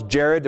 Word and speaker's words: Jared: Everybody who spells Jared with anything other than J-A-R-E-D has done it wrong.